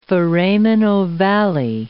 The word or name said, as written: foramen ovale